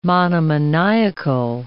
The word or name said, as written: monomaniacal